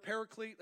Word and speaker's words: paraclete: That is the Greek word paraclete. That's how we pronounce it. It actually is pronounced differently in the Greek, but paraclete is how we would say it in America.